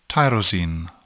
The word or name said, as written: tyrosine